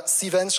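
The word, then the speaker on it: Cvent: Äh, Sevens schreibt man wie Event, nur das E weg und ein C davor. Also auf Sevens könnt ihr die Tickets kaufen.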